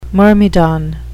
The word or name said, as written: myrmidon